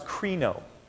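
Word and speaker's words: krino (κρίνω): This word is pronounced krino, and it means to rule, to determine the law, to decree, and to condemn.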